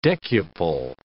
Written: decuple

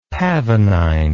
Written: pavonine

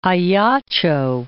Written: Ajaccio

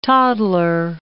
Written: toddler

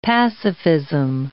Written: pacifism

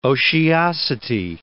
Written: otiosity

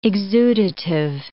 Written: exudative

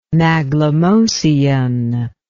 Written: maglemosean

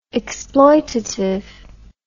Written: exploitatively

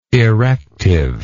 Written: erective

